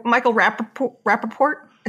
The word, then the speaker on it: Rappaport: But Michael Rappaport? Is that how you say his name? – Yeah, Rappaport.